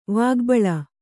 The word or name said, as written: ವಾಗ್ಬಳ